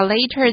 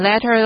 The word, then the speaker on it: later: 这边呢，我们看到一个字 phrase，就是片语的意思。这边的 ph 念作 f，复数呢就念作 phrases。But a year later, 一年之后呢，大家要注意一下 later 的念法。千万别念成 letter 咯。一年之后呢， She came down with a high fever，她就发了高烧。 Come down with 是个片语，有染上什么什么病的意思。那高烧这个字呢，我们用 high fever， H-E-V-E-R 念作 fever，也就是发烧的意思。接着呢，我们来看文章的最后一句话， She survived，她活了下来， S-U-R-V-I-V-E survive 有幸存的意思。